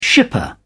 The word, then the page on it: shipper: British English